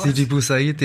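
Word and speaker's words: Sidi Bou Said: oder auch direkt nach Tunis beziehungsweise Sidi Bouaid, wie spricht man das aus? Sidi Boussaid, ja genau. Sidi Boussaid, genau.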